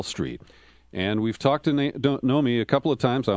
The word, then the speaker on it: Nomi: We're joined now by Nomi Prinz, who returns to our program. Her name is Nomi, N-O-M-I, like Naomi without the A, Prinz, P-R-I-N-S, and I spell it because I really want you to read her book.